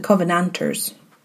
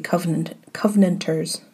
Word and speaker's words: Covenanters: In Edinburgh, you will pronounce this the Covenanters, but that sounds weird for me to say, so I'm going to say Covenanters.